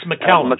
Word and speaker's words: McCallum: Just incidentally, for the record, the name is McCallum. – It's McCallum. – There are so many pronunciations that people come up with, but I just have to let you know that's the one I was born with.